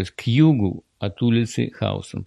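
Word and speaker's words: Houston: Город Хаустон и улица Хаустон пишутся одинаково по буквам, но здесь она читается Хаустон, а город называется Хьюстон.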